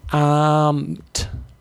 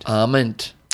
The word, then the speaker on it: Arment: I was asking Anne, she was her guide, and I was asking Anne about the correct pronunciation of Tina's surname, and apparently it's Arment, like Amen, with a T on the end. – Arment.